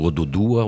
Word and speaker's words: Oduduwa: Na página do Odudua. O Odudua que se escreve, já tem até plágio do Odudua, né? O-D-U-D-U-W-A.